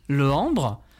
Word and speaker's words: Lehembre: Je m'appelle Cyril Lehambre, L-E-H-E-M-B-R-E, ça s'écrit comme ça.